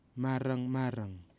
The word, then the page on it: MARANGMARANG: kwamera